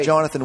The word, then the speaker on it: Jonathan: Jonathan, which, by the way, there is no extra H.